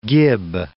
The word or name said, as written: gib